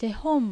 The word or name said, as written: Tehon